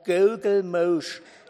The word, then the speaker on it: Gugelmusch: Also der wird ausgesprochen Gugelmusch.